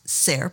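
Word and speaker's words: Saor: The name of the artist is spelled S-A-O-R-P-A-T-R-O-L. Now, he says Sayre Patrol, and it might very well be that, but I'm a little bit Celtic myself, and I say it's Shore Patrol.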